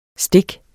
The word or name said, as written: stik